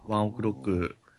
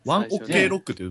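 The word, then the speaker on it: ONE OK ROCK: ワンオクロック、ぜひこの人、うん、関係ないですけども。 – ワンオクロック広がらなかったな。 – ワンオクロックって読めなかった、その最初。ワンオッケーロックって読めました、ね、僕、うん。 – ワンオクロックって読むんですね。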